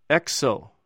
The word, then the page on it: exw: Greek